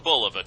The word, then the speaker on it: Bulova: America runs on Bulova time. – First of all, I've been saying it wrong. If they're saying it right, Bull of the Time.